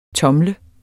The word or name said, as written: tomle